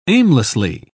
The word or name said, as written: aimlessly